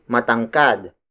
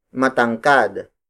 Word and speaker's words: matangkad: Tangkad is spelled as T-A-N-G-K-A-D. It means to have a slim and tall body. As an adjective, we say matangkad. Matangkad. Matangkad. Matangkad is spelled as M-A-T-A-N-G-K-A-D.